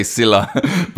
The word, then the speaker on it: Silah: And I just want to apologize for constantly saying Salah when I meant to say Silah, but, you know, I pronounced it wrong quite a lot.